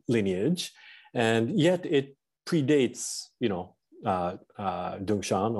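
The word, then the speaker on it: Dongshan: The latter character, Soto, the To is in Chinese way pronounced Dungshan, but in Japanese way we say Tozan.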